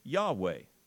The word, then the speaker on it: Yahweh: If you say I am in Hebrew, you say Yahweh. That's how you pronounce it.